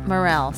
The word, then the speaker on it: Morrill: Hanley, and Sam Morrill You said it wrong, right? – Morrill, sorry. M-O-R-R-I-L.